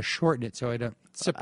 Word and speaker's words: Tsipras: Tsipras. – Alexis. You can't even really say T, can you? Or it's, no, I think it's just Alexis Tsipras.